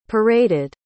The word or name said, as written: paraded